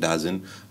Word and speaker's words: DAZN: They got a minor stake in DAZN, D-A-Z-N, DAZN. The people have a lot of pronunciations for that, but I call it DAZN.